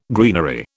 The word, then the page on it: greenery: British English